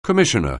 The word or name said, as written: commissioner